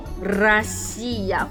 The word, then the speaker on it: Россия: Russia，那它的俄文的发音叫什么呢？Россия，俄罗斯人叫做什么呢？Rus，奇怪，明明叫做 Россия 或者叫做 Rus，如果我们翻译成中文，是不是应该叫做罗斯，或是像日本人讲的露西亚，或是罗西亚？